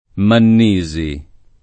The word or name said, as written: Mannisi